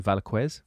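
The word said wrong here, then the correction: Velázquez: Velasquez. – Velasquez oh yeah yeah oh,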